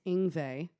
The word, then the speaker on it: Yngve: It's Yngve.